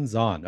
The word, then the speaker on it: Zhan: By the way, my name is pronounced Shawn Zhan. Shawn John is that's the P. Diddy clothing brand. So, yeah, all right. – It's a little bit different. – Shawn Zhan.